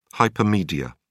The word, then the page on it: hypermedia: British English